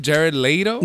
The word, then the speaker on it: Jared Leto: That's how you say his name, Jared Leto.